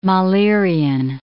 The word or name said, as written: Mahlerian